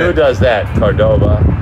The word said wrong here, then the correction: Qdoba: Qdoba. Yeah. – I said that wrong? – Yeah. – Qdoba? – Qdoba.